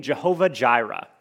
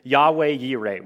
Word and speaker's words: Yahweh Yireh: Or, if you want to be a little bit more accurate to a Hebrew pronunciation, Yahweh Yireh, which you will hear me use for the most part. Latin people like to add J's to things. They didn't exist in Hebrew.